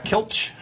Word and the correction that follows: Klipsch: How do you pronounce Klipsch. – It? Klipsch. Whatever.